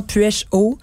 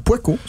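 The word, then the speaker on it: Puech-Haut: P U E C H très H-A-U-T. Ce qu'on prononce, ce qu'on prononce souvent puèche-o. C'est Poico, c'est ce que je, dis.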